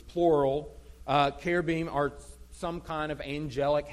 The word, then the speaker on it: cherubim: Now, I may have just thrown you off by the way I pronounce cherubim. In the Hebrew, that's a k and not a ch.